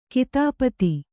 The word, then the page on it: ಕಥಾಪತಿ: kathāpati